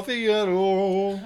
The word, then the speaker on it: Figaro: I'm pronouncing that right? I'm not retarded? – Figaro. – Oh, okay.